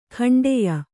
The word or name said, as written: ಖಂಡೆಯ